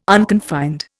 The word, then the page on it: unconfined: Indian